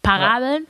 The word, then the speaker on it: Parables: Parables. Mhm. Spricht man das so aus im Englischen? Parabeln. Ja.